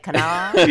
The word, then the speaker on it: Kanawha: But it's spelled Kanawha, but you're saying it. I was saying it the way you were saying it, which is like kind of with a drawl, like Kanawha.